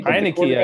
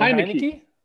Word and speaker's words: Heinicke: Heinicke, I believe. Isn't it Heinicke? Yeah.